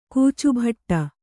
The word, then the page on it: ಕೋಚುಭಟ್ಟ: kannada